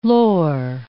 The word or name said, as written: lore